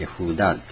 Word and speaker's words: ユダ: そこからやだからユダが出たわけです。ヤフーダーって言うんですけどね、本当の発音。